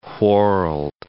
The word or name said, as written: whorled